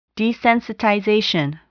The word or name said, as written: desensitization